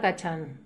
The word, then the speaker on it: Cachán: Mi nombre es Mariana Cachán. – Cachán, ¿cómo se escribe? – C-A-C-H-A-N.